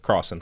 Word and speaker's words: Crossen: Crossen.